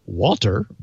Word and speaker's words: Wouter: I got a very nice note from the Netherlands from someone who just said his name was Wouter, W-O-U-T-E-R. He gave me permission to pronounce it Walter, but he also told me how to pronounce it. So it's Wouter.